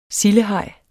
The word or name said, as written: sildehaj